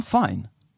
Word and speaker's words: Fiennes: Heter han Fine? – Ja, Ralph uttalar man namnet egentligen.